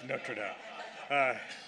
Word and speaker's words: Notre Dame: where we pronounce it right. It's Notre Dame.